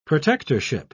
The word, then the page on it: protectorship: British English